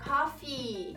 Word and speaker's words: coffee: Muita gente acaba pronunciando como se fosse tosse, porque tossir cough. E café, você prolonga o i, então fica coffee. Gostou?